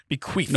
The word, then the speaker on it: bequeathed: Also, make sure you hard pronounce the TH in queeth. – Yeah, I got you. Yeah. Yeah, bequeathed. Bequeathed. – No, not an F.